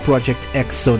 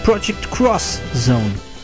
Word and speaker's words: Project X Zone: And I was just thinking about Project X Zone, which is not pronounced Project X Zone, of course. It's Project Cross Zone.